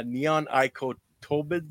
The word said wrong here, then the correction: neonicotinoids: Yeah, neonicotinoids. You missed a syllable there.